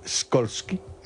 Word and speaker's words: Skolsky: ¿Cómo se pronunciaría en polaco Skolsky? – Skolsky. Como tú lo has dicho. – Bueno. – Skolsky, fondo a la derecha.